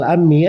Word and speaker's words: al-Ammi: Ini bacanya al-Ammi ya. Al-Ammi itu nisbat kepada sejumlah ahli hadis. Karena dia kalau menyampaikan hadis dia selalu berkata menceritakan kepadaku pamanku, pamanku. Makanya dibilang al-Ammi gitu.